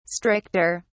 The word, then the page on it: stricter: British English